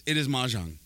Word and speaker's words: Mahjong: So like right now they got like a Christmas event and they have this puzzle game and it is Mojang, am I saying it right? – I think it's Mahjong. – Mahjong? – The tile game? – It is Mahjong.